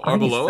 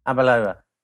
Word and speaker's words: Arbeloa: Oh, Arbeloa? 33. – Yeah. – Yeah. – Arbeloa.